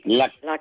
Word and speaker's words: Laxma: 2 comprimidos antes de la comida, es Laxma, tal como se pronuncia, ¿no? – Con X, sí, Laxma.